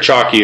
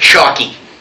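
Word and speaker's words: Chalke: Is that how it's pronounced? – That's how I say it. – I was under the impression it was chalk. – I believe it's chalk.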